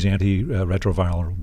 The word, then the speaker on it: antiretroviral: And so there was a lot of business for Indian pharmaceutical companies providing these antiretroviral drugs.